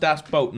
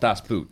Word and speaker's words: Das Boot: So it's pronounced das boat, not das boot.